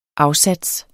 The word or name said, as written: afsats